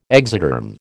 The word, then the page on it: exoderm: American English